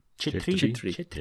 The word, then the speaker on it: Chhetri: And most of the rulers, I think the Shah family and all of the military leaders within their kingdom would have been Chhetri, is that how we said we pronounce the word? – Chhetri. – Yeah.